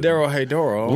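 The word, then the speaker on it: Dorohedoro: Then we got Doro Hadoro. – It says Doro He Doro. – Doro He Doro.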